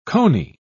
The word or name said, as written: coney